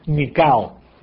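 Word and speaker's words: Nikao (νικάω): Así como suena en el español, así se escribe, Nikao.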